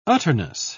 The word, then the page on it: utterness: British English